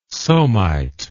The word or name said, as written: somite